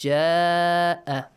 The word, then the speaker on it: جاء: Hamza and Alif Mad both are in the same word, in one word. Ja-a. So, six counts, you gotta pronounce the Mad, okay? Stretch it for six counts.